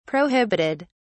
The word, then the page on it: prohibited: British English